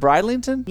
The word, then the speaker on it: Bridlington: Is it Bridlington? – Yeah, Bridlington.